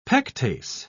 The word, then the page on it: pectase: British English